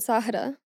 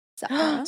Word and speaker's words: Zahra: Zara, not like the store, but in Arabic, you pronounce it Zahra. Zahra.